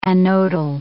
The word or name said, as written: anodal